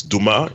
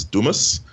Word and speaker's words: Dumas: I don't know why he pronounces his name that way. It's Dumas in French, I think, which it was originally from. But but anyway, he's American fully now, so he pronounces Dumas.